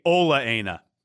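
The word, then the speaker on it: Ola Aina: Speaking of Chelsea, another winner, and you're going to have to maybe help me with the pronunciation, the left back, Ojaina. Is that how you say it? – It's actually Olaena.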